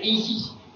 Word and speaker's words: Isis: Es el mantra de los misterios Siago, que se pronuncia Isis, en dos, se reparte en dos sílabas.